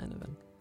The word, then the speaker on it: Enevun: I also post on Twitter and I upload videos to YouTube both under the same name, Enevun. – And how do you spell Enevun? – Enevun is spelled E N E V U N.